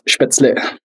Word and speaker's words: Spätzle: That's Spätzle is how you say it? Spätzle? – Yes, Spätzle.